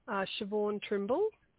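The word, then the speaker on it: Shevaun Trimble: Siobhan Trimble.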